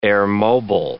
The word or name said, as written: airmobile